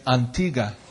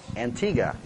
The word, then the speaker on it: Antigua: Is Americans say Antigua, but they say Antigua. – Antigua.